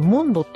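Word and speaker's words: 主水: 島根料理とサバシャブの店、これモンドって読むんかい、中村モンドさんのモンドだよね、酒水って書くの。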